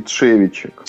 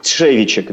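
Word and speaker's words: Trzewiczek: как правильно фамилия-то произносится? – Игнаций Тшевичек. – Тшевичек, да-да-да.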